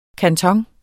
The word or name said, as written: kanton